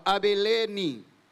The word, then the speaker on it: Abilene: And the region of Trachonitis and Lysanus, tetrarch of Abilene. Emphasis on the L-E.